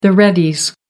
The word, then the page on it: readies: American English